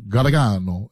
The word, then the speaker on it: Gargano: It's supposed to be, like, the old world says it, like, Gargano, Gargano is how you say it.